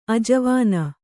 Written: ಆಜವಾನ

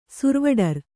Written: ಸುರ್ವಡರ್

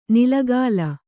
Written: ನಿಲಗಾಲ

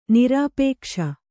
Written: ನಿರಾಪೇಕ್ಷ

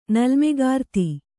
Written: ನಲ್ಮೆಗಾರ್ತಿ

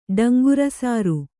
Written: ಡಂಗುರಸಾರು

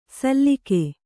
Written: ಸಲ್ಲಿಕೆ

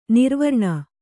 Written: ನಿರಾವರಣ

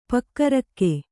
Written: ಪೊಗರುಗು

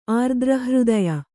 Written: ಆರ್ದ್ರಹೃದಯ

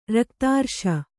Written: ರಕ್ತಾರ್ಶ

